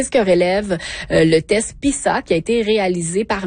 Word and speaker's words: PISA: Je rappelle que le PISA, en fait, ouais, PISA plutôt, hein, parce que c'est un S entre deux voyelles, ça se prononce comme un Z.